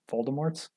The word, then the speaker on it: Voldemorts: Voldemorts? – Yeah, you nailed it.